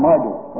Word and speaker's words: Marduk: Only in Hebrew it's pronounced Merodach, and in Babylonian Marduk, same God, same order.